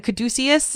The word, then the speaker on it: caduceus: A caduceus? – Yeah.